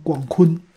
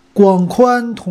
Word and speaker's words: 广宽: 广宽通话，我总是能念成广坤。广坤童话，谢广坤，总想起他。哎，怎么打出广坤了呢？广宽童话啊，给你也打进去了。